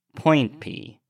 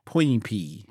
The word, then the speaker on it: Poinpy: Point P. – Point P.